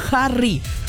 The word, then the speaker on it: Harry: Ya sé que no se escribe con J, que se escribe con H, pero se pronuncia Harry.